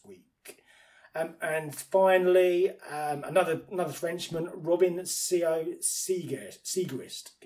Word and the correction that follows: Robin Sciot-Siegrist: get his name no, pronounced correctly, Robin Sciot-Siegrist, 80 to 1.